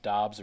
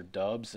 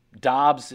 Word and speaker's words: Doubs: And both of these guys, Wes, really interesting prospects in my mind, because certainly Doubs, and there's been all this confusion as to whether it's pronounced Doubs or Dubs, and apparently he's cool with both. – Yeah, he's actually fine with both, because apparently Dubs was kind of a nickname. Doubs is what he says is the way it's supposed to be pronounced, but he really doesn't care either way.